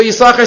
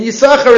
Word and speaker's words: Yisachar: and that's why the second sin in Yisachar is silent, because he gave it to his son.